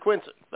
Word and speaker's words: Quincy: But if you pronounce it Quincy, they know you're not from there because you pronounce the C like a Z. It's Quincy. – Quincy.